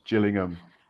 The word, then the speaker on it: Gillingham: It's Jill's in the blood because it's Gillingham. – Yeah, but if you, do it with a, you do it with a hard G and you search on YouTube, you get a very different channel.